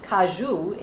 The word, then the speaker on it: caju: I'd always known the cashew, which is pronounced caju in Portuguese, to be a nut.